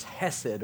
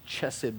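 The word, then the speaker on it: chesed (חסד): The Old Testament Hebrew word that spoke of that relationship was hesed, or the Hebrew is kind of a ch, chesed, but if I tried to pronounce it, I would spit over half of you, so I'm not going to do that.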